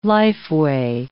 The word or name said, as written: lifeway